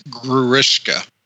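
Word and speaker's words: Grewishka: I don't know if we're going to touch upon this later, but that last battle almost felt like how do you say Gruetha? – It's Gruishka.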